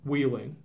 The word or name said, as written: Wheeling